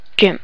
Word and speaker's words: GIMP: And again, I pronounce it GIMP.